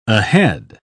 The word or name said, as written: ahead